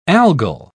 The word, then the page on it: algal: British English